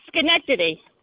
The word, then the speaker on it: Schenectady: Oh, Schenectady.